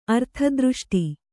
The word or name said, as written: ಅರ್ಥದೃಷ್ಟಿ